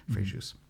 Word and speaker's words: Fréjus: Our kids are fairly young at the time, and town called Fréjus, and they pronounce the S in the south, Fréjus.